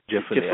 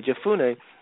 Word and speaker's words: Jephunneh: Two of the men had explored the land, Joshua son of Nun and Caleb son of, how do you pronounce that, Oshel? Jephunneh. – I believe it's, Jephunneh. I believe that's pronounced it. – Mm-hmm. Okay, Jephunneh.